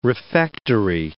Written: refectory

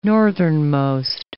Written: northernmost

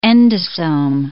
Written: endosome

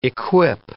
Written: equip

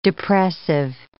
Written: depressive

